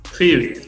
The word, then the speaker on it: Phoebe: Phoebe, acuérdense, Phoebe, como la de Friends. Se escribe Phoebe. Nada que ver cómo se suena.